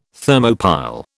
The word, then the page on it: Thermopile: British English